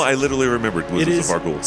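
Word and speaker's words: Vargouille: It does have an E at the end, so you should pronounce the L, so Vargul.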